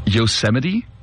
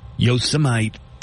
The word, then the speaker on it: Yosemite: Yosemite? – Yosemite. – It's, yeah, it's not Yosemite. Nobody should be a Yosemite.